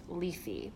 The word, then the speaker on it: Lethe: So I've been really recently obsessed with the mythology around Hypnos and the river Lethe, which is pronounced Lethe, but I can't train my brain to say it like that.